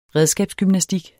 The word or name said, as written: redskabsgymnastik